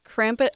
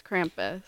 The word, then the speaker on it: Krampus: Did I say it right that time? – Yeah, you did. It's Krampus. I believe it's Krampus.